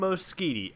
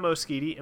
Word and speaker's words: Moschitti: it's Dom Moschitti at Dom Moschitti, and Moschitti is spelled M O S C H I T T I.